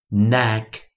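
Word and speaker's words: knack: j j jack jack jack，下一个我们发音呢，我们是 k n，注意 k n 的时候啊，在自然拼读中我们只念一个音，就是 n 的 n n n neck neck neck，下一个 l 我们发音为 l l l。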